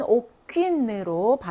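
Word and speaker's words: aucune: 그래서 옥강 또는 여성으로 쓰이면 옥퀸으로 발음이 조금 달라지죠.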